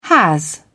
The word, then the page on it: has: British English